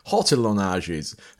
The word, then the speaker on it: hortillonnages: in the summer, you can go to the hortelages. I always say this wrong. Hortillonnages. That sounds better.